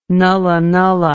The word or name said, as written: nulla nulla